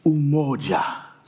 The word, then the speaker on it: umoja: It's called umoja. Umoja. Can you say that with me? Umoja. No. Don't say it like you're American or Michiganders.